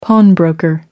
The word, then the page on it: pawnbroker: American English